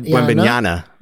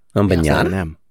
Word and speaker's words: Wembanyama: He said Wembanyama with an N at the end. – Wembanyama.